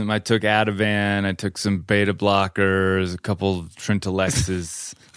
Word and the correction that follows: Trintellix: Say it right. What's it called? Sorry. Trintellix. Trintellix.